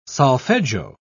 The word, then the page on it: solfeggio: British English